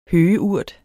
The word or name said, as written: høgeurt